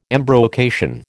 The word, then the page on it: embrocation: American English